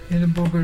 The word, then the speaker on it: Irribuga: Edinburgh is a dolphin. You spell it I-R-R-I-B-U-G-A.